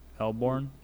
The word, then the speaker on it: Melbourne: Melbourne? – Melbourne. That's how you say it.